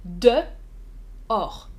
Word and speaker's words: dehors: Hmm, tu as écrit donc d'ailleurs, non, en français on dit effectivement dehors. À l'extérieur, dehors. Dehors. Difficile, c'est vrai. deux syllabes, hein. dehors, dehors, dehors. Même on peut parfois entendre comme le chiffre 2, dehors, dehors de, hmm, c'est un peu plus léger, de, de, dehors.